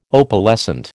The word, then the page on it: Opalescent: American English